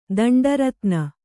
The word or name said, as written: ದಂಡರತ್ನ